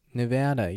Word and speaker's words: Nevada: Now, in case anyone's curious, Nevada, yes, that is the correct pronunciation.